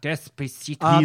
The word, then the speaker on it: Despacito: You said it wrong. – I did say it wrong. – Well, you know. – Despacito.